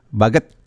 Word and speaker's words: Baguet: Karena saya tadi cari-cari nih tulisannya kan bahasa Baguet ini bacanya apa ya Baguet ini ya? Baguet atau apa? Sampai saya cari di Translate Google ternyata Baget bacanya begitu ya Bu Tasya ya.